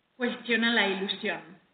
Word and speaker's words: Cuestiona la ilusión: Cuestiona la That song is hypnotic, and I understand I didn't quite pronounce it right, so why don't you say it? – Cuestiona la ilusión. – Cuestiona la ilusión.